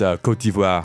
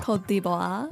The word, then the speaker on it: Côte d'Ivoire: So in French, it's Côte d'Ivoire. – Côte d'Ivoire.